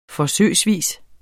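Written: forsøgsvis

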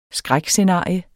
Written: skrækscenario

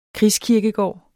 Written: krigskirkegård

